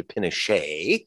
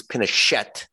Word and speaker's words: Pinochet: Now, for those too young to know who Pinochet was, and some people Frenchify the name to Pinochet, he's Pinochet, which, by the way, in the reason my friends in Chile insist on calling him Pinochet is not only because it's correct pronunciation of the Spanish, but Pinochet is also colloquialism for small penis, or Pinochet, like Pinocchio the liar.